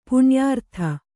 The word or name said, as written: ಪುಣ್ಯಾರ್ಥ